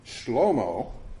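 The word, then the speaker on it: Shlomo (שלמה): We don't know anything about Dodo, but I will just point out to you that the Hebrew name Shlomo, which sounds like it might be the fifth Marx brother, is actually the way Hebrew pronounces the name Solomon, the wisest pure human being in history.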